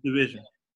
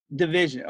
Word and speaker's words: DVSN: Divsfin, DVSN, however you pronounce that. – It's Division. – Division.